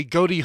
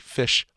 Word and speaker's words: Ghoti: Or depending on how you pronounce it, could be Fish Hook. That's one of those funny jokes about G-H-O-T-I, is that you can pronounce it Fish, depending on how you look at it.